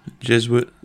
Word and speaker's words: Jesuit: Am I saying that right? Jesuit? – Jesuit.